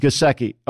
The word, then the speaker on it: Gesicki: Is it Gesicki? Gesicki. Okay. I mean, you know, he can run, but he didn't have rare speed.